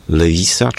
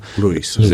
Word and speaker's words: Lewis: No, oczywiście oryginalna jest Lewis, natomiast w Polsce przed wojną na przykład mówiło się Lewis.